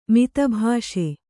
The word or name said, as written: ಮಿತಭಾಷಿ